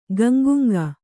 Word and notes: ಗಂಗುಂಗ: kannada

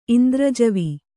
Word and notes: ಇಂದ್ರಜವಿ: indrajavi

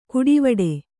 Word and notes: ಕುಡಿವಡೆ: kuḍivaḍe; Kannada

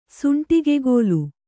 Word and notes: ಸುಂಟಿಗೆಗೋಲು: suṇṭigegōlu

